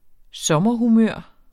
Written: sommerhumør

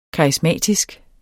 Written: karismatisk

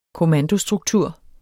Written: KOMMANDOS

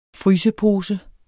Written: frysepose

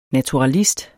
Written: naturalist

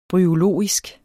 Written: bryologisk

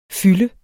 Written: fylde

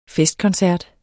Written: festkoncert

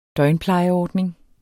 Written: døgnplejeordning

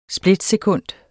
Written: splitsekund

